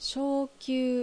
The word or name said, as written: Shoukyuu